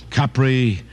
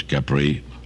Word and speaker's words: Capri: And if you want to pronounce it Capri, you'd be right. But almost everybody says Capri.